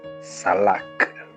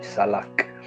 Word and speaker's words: salach: And the meaning, or the Hebrew rendition, is salak. It's spelled S-A-L-A-C-H, salak.